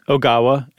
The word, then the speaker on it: Ogawa: Ogawa, and it's Seiji.